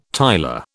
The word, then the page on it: tiler: British English